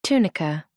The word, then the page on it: tunica: American English